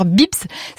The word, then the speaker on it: Beebs: Vous avez Vinted, sinon vous avez encore Bips, ça s'écrit B-E-E-B-S, ou encore de déstockage comme Scoléo.